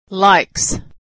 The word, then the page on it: likes: ending s